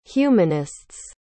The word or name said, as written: humanists